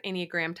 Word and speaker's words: Enneagram: Like I said, we're going to base this episode on the 9 different Enneagram types. I'm saying that right. I always mess that word up, right, Audrey? I don't know. – You got it.